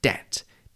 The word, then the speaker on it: debt: The word for money that you owe someone was D-E-T, but it was thought good to show its Latin origins, so a silent B was added, hence debt, D-E-B-T.